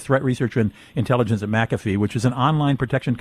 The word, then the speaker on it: McAfee: Is it McAfee or McAfee? How do you pronounce it? – It's McAfee.